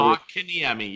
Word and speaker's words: Kotkaniemi: You draft a player in your first round in Kukkonen, I can't pronounce it, Kukkonen or whatever. – Kotkaniemi, yeah.